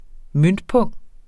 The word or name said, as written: møntpung